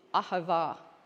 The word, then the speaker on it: ahava: is the Hebrew word for love is ahava.